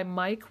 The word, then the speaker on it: Meik: This book is written by Mike Wicking, Mike is spelled M-E-I-K.